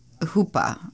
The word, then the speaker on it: chuppah: Our chuppah, which is the Jewish wedding canopy, chuppah, I guess it's pronounced, yeah, C-H-U-P-P-A, chuppah, was simple.